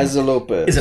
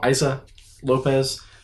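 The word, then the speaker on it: Issa López: Isa Lopez. – Is it Isa? – I think so. – I-S-S-A. So, Isa Lopez.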